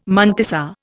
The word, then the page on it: mantissa: Indian